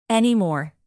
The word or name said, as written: anymore